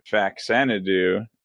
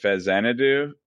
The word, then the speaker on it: Faxanadu: Faxanadu, and that's a really good game. – Yeah. – Although the guy in the video pronounced it Fazanadu, which I've seen Xanadu before.